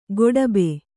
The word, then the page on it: ಗೊಡಬೆ: goḍabe